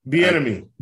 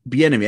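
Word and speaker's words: Bieniemy: Benemy or whatever. He interviewed, you know, Chiefs office. – Bienemy. – Bienemy.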